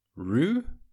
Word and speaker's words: Ruuh: It's called Roo. I hope I'm pronouncing that right. It's spelled R-U-U-H.